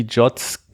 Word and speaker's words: JWT: Die haben auch die JOTs massiv gepusht, also das JWT spricht man JOT aus, um das irgendwie... – JOT. Aber das kannst du natürlich so sagen, das ist dann falsch, aber... – Mh. Nee, das sagen sie selber so.